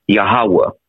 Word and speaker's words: Yahweh: If you want to go into biblical history, the correct pronunciation of the most or the creator would be Yahweh or Yah.